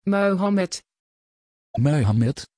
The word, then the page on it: Muhammed: Dutch